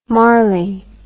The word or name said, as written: Marley